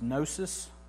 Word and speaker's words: gnosis: And the first one is gnosis. The G is silent. It's not gnosis. Not a gnat that you smack on your arm, right?